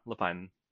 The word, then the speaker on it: Lapine: Yeah, Lapine.